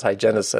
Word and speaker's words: hygienist: And there was actually, I remember the, and apparently I'm saying this word wrong because I pronounce hygienist as two syllables. It must be three, hy- hygienist.